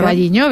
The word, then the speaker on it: O Carballiño: O Carballiño, no lo sé pronunciar. – O Carballiño.